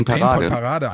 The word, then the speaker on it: Pain Point Parade: Du hast sie jetzt gerade so cringe amerikanisch ausgesprochen. Die Pain Was? Point Parade. – Ja, können wir uns auf die Pain Point Parade einigen? – Klar, können wir das.